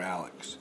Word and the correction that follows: Alex: Alec, it's Alec. Alec. I mean, it's spelled Alex, but it's pronounced Alec.